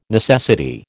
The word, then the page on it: necessity: American English